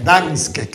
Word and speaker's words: Gdańsk: Hemos estado también en Gdańsk. En Gdańsk, que se escribe G-D-A-N-S-K. ¿Os suena de algo? – No, no.